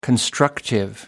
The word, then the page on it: constructive: British English